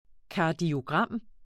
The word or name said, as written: kardiogram